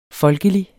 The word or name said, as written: folkelig